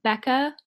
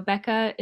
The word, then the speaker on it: Bekah: oh yeah bekah period marathon runner girl so bekah is spelled b-e-k-a-h Okay, awesome.